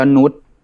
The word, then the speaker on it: Nut: นุษเป็นหรืออาจจะอ่านว่านูตก็ได้ N U T